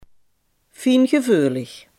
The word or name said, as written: fijngevoelig